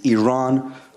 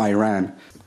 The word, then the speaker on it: Iran: You're pronouncing our country's name wrong. Our country is named Iran, not Iran.